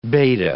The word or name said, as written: beta